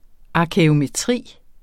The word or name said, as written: arkæometri